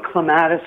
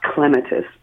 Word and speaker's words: clematis: The proper pronunciation is from the Greek, klema, to climb. So properly, they're pronounced clematis because of the clema to climb part of it.